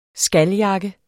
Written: skaljakke

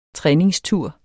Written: træningstur

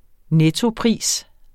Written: NETTOPRISEN

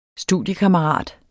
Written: STUDIEKAMMERAT

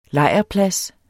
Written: lejrplads

